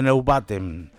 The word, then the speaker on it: Neubauten: Estoy hablando de los la banda, la leyenda industrial de Berlín, los alemanes de de Neubaten. – Qué nombre difícil para decirlo. – ¿eh? la pronunciación perfecta alemana mía. – Muy bien, así me gusta. – Dice que que está correcto.